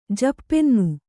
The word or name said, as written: ಜಪ್ಪೆನ್ನು